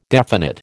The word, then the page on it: definite: American English